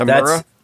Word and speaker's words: Temuera: So the actor's name was, I don't know if I pronounced his first name right, Tamura Morrison. – That's right, that's right. – Is it Tamura? – I believe so.